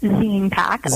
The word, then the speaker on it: ZinePak: So tell us about yourself and a little bit about your company, ZinePak. – Sure. So it's actually pronounced ZinePak, like magazine package.